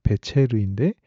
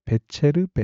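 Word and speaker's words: 배체르인데 (בֶּצֶר): 원문, 원어로 보게 되면 앞에 있는 보화라고 하는 단어는 금이라는 뜻의 배체르인데 뒤에 나오는 돌의 이름 발음은 배추루입니다 베체르 베추르 이렇게 비슷한 발음이고요.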